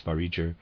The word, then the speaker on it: Vareger: Readers aside, I'm pronouncing this Variger. It's spelled V-A-R-E-G-E-R.